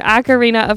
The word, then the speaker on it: Ocarina: What color is Saria's hair in Ar- Ocaran- Ocarina? Ocarina? Okay, I'm sorry, I was corrected on this. What color is Saria's hair in Ocarina of Time? – green.